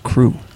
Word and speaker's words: Kreuk: But to my defense, I just think Kristen Kreuk is awesome. – I believe it's pronounced crew.